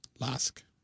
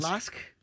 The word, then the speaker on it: LASK: LASK. – LASK.